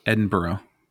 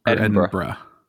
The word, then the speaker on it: Edinburgh: Edinburgh. – Did I say that right? Edinburgh. Is it Edinburgh? Edinburgh. That's what I'm going to say. – You just got to compress that sound.